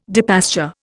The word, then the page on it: depasture: Indian